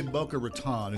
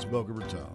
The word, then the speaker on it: Boca Raton: Boca Raton. Some people say Boca Raton. It's Boca Raton.